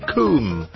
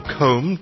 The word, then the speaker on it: Combe: We've got Ivan Combe, or Combe, depending how you pronounce it, C-O-M-B-E.